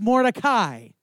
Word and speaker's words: Mordecai: It is actually pronounced Mordecai.